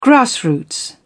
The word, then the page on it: grassroots: British English